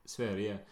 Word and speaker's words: Sverige: Sverige.